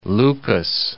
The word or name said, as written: Lucas